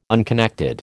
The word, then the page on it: unconnected: American English